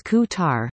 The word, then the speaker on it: Qatar: It's Qatar.